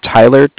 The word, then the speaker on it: Tyler: My name is Tyler, T-Y-L-E-R. – Uh-huh. – And I'm calling with Cardiff. It's spelled C-A-R-D-I-F-F, as in Frank.